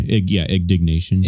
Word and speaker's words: indignation: Silent Planet has a message that burns with a hardcore ignitation. I know the word, but I'm fumbling over how to say it. – It's in their Spotify bio. – Yeah. While coupled with progressive instrumentation. – Oh, okay. Indignation.